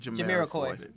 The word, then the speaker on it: Jamiroquai: Jamiroquai.